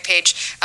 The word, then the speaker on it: Page: So it's a play on my name, A Page of Mary, Mary Page. And my name is spelled P-A-G-E.